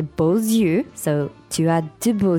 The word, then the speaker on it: beaux yeux: Beau, but because eyes is plural, we say beaux yeux. – Yeah, that's the liaison. – Because normally the X at the end of beau is silent, but here you have to pronounce it.